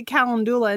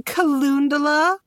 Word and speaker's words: calendula: The lady at the apothecary, every time I go, corrects me. Because I say calendula, and she goes, don't you mean calendula? I'm like, no, bitch, I said calendula.